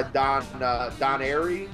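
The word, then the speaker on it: Don Airey: now I do love that he's, you know, currently his slot, you know, he's passed away, is filled by Don Airy.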